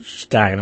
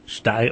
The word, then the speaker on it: Steyn: Anyway, this Imprimis thing that was put out, there was a fellow named Mark Stein, I think he pronounces him, S-T-E-Y-N, Stein, I don't know by the way you can pronounce it.